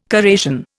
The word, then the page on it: corrasion: Indian